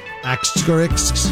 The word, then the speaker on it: asterisk: Asterisks. How do you say that word? – It's asterisk. – Asterisks.